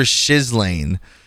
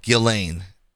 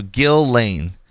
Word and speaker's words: Ghislaine: It said, aim for Shizlane. But Vicky Ward, a journalist who was early to Epstein stories, uses what I think is the right pronunciation, Ghislaine. So Ghislaine. And Ghislaine Maxwell's brother, Ian, says it very casually, like Ghislaine.